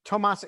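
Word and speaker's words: Toomas: Tomas Ilves.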